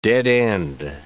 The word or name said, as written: dead-end